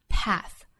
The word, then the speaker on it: path: 下面 from path to path, 那 path 这个词字母 A 在美音中读成 A 的元音 ,path, from path to path, no man in sight.